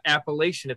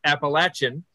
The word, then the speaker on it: Appalachian: And you better pronounce it right. It's not Appalachian, it's Appalachian.